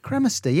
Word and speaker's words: cremaster: Cremaster. Oh, I don't even know how you say it. – Cremaster. Yeah.